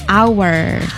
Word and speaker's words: hour: Jam, so when it's related to time, so actually this should be pronounced with hour. – Hour. – The H is silent.